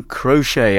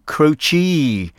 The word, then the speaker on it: Croce: Singer-songwriter Jim Crochet, I think is how most people pronounce his name, but I believe it's not actually the official pronunciation. It should be Jim Croce, with time in a bottle.